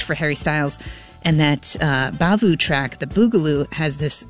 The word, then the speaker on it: Bavhu: And actually, in between all those was the Boogaloo, aka Fiesta, with the band that is entitled Bavu, but it's spelled B-A-V-H-U and it's capitalized.